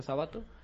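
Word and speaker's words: Sábato: Se escribe Sabato, pero se pronuncia Sabato.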